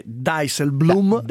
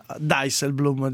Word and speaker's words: Dijsselbloem: Allora, detto che un ascoltatore ci scrive, mia moglie che è olandese dice che si dice Dijsselbloem, abbiamo basta con questa versione dall'Olanda profonda. Dijsselbloem, direi che chiudiamo la questione della pronuncia.